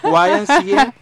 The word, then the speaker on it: YMCA: YNCA. – Sí, YNCA, yo todavía no la sé pronunciar. – YMCA o conocida como la Asociación Cristiana de Jóvenes.